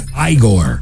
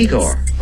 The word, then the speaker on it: Igor: This classic flick featured a character whose boss mispronounced his name as Igor. – You must be Igor. – No, it's pronounced Igor.